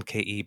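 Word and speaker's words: Ke: Yeah, it's written K-E, but it seems on the radio and whatnot, they seem to be pronouncing it K.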